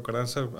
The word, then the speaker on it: Ocaranza: I'm Dr. Hector Ocaranza. A lot of the people know me as Dr. O, basically because it's quite hard to pronounce all the letters on my last name.